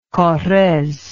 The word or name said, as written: correze